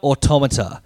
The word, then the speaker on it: automata: Nier Automata. Is that how you pronounce the... – It's how you pronounce automata if it's not a game title.